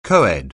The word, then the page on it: co-ed: anglais